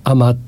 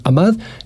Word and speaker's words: Ahmad: Así fue como Frederick Russell Jones, al que de niño llamaban Fritz, se convirtió en Ahmad Jamal. A propósito de su nombre musulmán, muchos lo pronuncian Ahmad y otros Ahmad. Yo a veces equivocadamente seguramente, intercambio entre Ahmad y Ahmad.